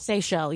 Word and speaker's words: Seychelles: Is it pronounced Seychelles? – Seychelles, yeah. – The Seychelles, the yeah. Island, islands. – S-E-Y-C-H-E-L-L-E.